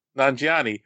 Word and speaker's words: Nanjiani: Nanjiani.